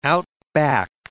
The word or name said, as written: outback